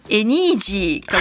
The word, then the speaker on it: Anyji: エニージー株式会社、はい、あのアルファベットで ANYJI と書いて、はい、エニージー株式会社なんですね。